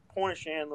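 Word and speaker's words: Pointe-aux-Chênes: And it's right on the tip of Point of Shan, Louisiana, which is down in the marsh.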